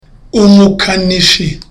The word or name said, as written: umukanishi